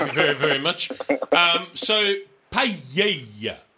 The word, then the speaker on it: paella: It's paella. So double L in Spanish is pronounced Y. So just think of it that way.